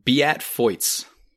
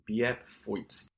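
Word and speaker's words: Beat Feuz: That is my favorite professional ski name, because it's spelt like Beat Fuse.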